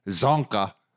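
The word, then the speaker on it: Csonka: The best one was Larry Szonka. – Okay. Never again. – It's pronounced Szonka.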